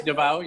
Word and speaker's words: Davao: I think it's Davao, is that how you pronounce it? – D-A-V-A? Yes, Davao, yes.